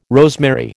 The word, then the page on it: rosemary: American English